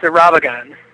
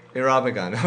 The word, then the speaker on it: Irabagon: Am I pronouncing it correctly? – Yep, it's Irabagon. Right. – Irabagon, okay.